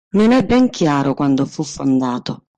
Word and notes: chiaro: Italian

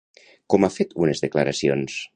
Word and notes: unes: Catalan

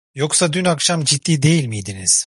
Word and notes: ciddi: turkish

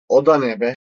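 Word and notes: O: Turkish

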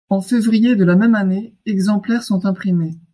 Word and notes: exemplaires: french

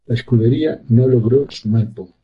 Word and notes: sumar: ispanskii

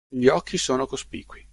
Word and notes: occhi: italian

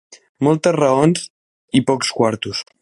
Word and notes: quartos: catalan

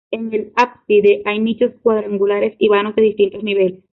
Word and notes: cuadrangulares: ispanyolca

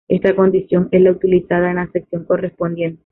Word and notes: correspondiente: ispanskii

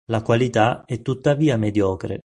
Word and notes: mediocre: italian

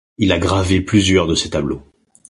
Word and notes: tableaux: French

